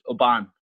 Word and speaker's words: Oban: It's actually pronounced. My name should be pronounced Oban.